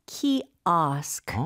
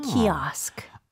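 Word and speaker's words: kiosk: 영어로는 키오가 아니고 키오스크. – 아. – 키오스크. – 오가 아에 가깝게 발음이 되네요.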